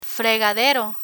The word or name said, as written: fregadero